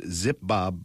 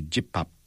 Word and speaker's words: Jipbap: So it's pronounced Zipbob, but it's actually Jipbap. – Yeah.